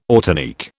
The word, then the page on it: ortanique: British English